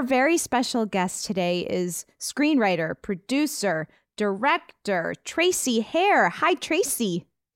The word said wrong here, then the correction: Sayre: Tracy Sayre. It rhymes with hair.